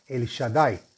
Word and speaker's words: El Shaddai: Se escribe Aleph Lamet, Shin Dalet Yud y se pronuncia El Shaddai.